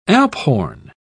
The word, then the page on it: alphorn: British English